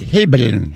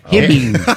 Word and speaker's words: Hibbing: You pronounced it Hibbin. – Hey. – Hibbing.